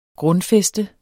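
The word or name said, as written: GRUNDFÆSTET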